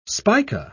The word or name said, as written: spica